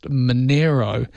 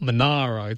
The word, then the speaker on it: Monaro: And having lived down that area, having lived in the Snowy Mountains, I know it's pronounced Monero and not Monaro, too.